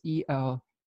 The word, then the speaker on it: Eleusis: As she was picking flowers in a field near Ulysses, or Eleusis, I don't know how to pronounce that. That is Eleusis.